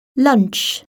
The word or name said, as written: lunch